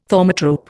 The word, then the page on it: thaumatrope: Indian